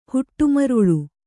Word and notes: ಹೊಡೆಮರಳ್: kannada

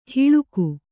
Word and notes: ಹಳಗೆ: Kannada

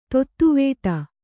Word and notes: ತೊತ್ತುವೇಟ: tottuvēṭa

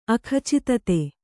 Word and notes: ಅಖಚಿತತೆ: Kannada

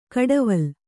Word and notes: ಕಡವಲ್: kaḍaval

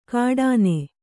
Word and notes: ಘಟನೆ: Kannada

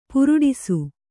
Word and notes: ಪುರುಡಿಸು: puruḍisu

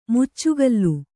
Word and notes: ಮುಚ್ಚುಗಲ್ಲು: muccugallu

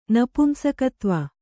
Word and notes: ನಪುಂಸಕತ್ವ: napumsakatva

